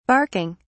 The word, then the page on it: barking: British English